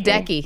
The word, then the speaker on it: Ledecky: Is that how you pronounce her last Ledecky. – Name? – Ledecky.